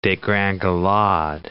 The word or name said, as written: degringolade